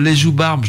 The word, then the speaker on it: Les Joubarbes: Marie Rubens en direct des studios de Radio Canu avec le morceau Les Joubarbes, je le dis bien ce coup-ci.